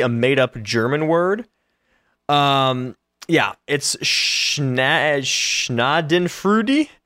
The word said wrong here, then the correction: Schadenfreude: It's Schadenfreude. It's made up from the German words for damage and joy, and it means taking pleasure in the misery of others.